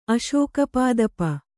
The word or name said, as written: ಅಶೋಕಪಾದಪ